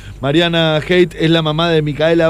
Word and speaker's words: Heit: Hate se escribe H-E-I-T, donde están organizando un sorteo con la gente de una importante pinturería de nuestra ciudad, Chino.